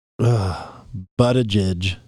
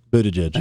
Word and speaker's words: Buttigieg: Transportation Secretary Pete Mayer. Buttigieg. – That's it, yeah. – Buttigieg.